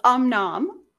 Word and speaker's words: Om Nom: I keep saying om-num-num, but I'm like, I know it's the wrong name. – Well, you're close. Om nom.